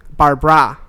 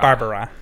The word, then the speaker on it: Barbra: Which is spelt B-A-R-B-R-A. Barbara. – Barbara. Barbara. – Not Barbara. – No. – No. Nobody needs an extra vowel or syllable in here.